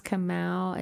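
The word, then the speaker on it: Kamauu: After that was Clover by, I think it's pronounced Kamau. It's K-A-M-A-U-U.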